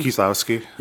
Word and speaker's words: Kieslowski: Kieslowski.